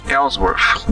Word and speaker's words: Ellsworth: Jerry Ellsworth. Né? Como pronuncia esses nomes? – Ellsworth.